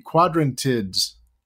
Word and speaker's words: Quadrantids: The Quadrantids. That wasn't terrible. – That was maybe your best ever. I mean, that so much for this planetary radio holiday tradition of hearing you attempt to pronounce it. I think you got it.